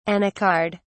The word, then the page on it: anacard: British English